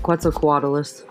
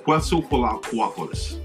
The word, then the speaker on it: Quetzalcoatlus: Quetzalcoatlus. – Quetzalcoatlus. – Quetzalcoatlus. – It sounds like you're saying pretzel.